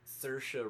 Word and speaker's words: Saoirse: The movie starring Saoirse Ronan, which apparently is how you pronounce her name.